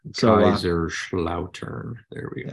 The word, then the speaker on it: Kaiserslautern: So our German team this year is FC Kaiserslautern. – That's the one. Yes. – Yeah. That's probably just completely butchered that, but I know it's a Schlotten. – Kaiserslautern.